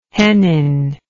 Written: hennin

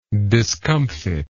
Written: discomfiter

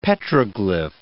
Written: petroglyph

